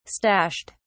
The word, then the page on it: stashed: British English